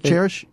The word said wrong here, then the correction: Charis: It's Charis. – Charis. – Charis is the word in Greek for grace.